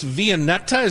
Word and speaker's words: Viennetta: So, Amy, tell me about this Viennetta. Is that how you say it? – It is.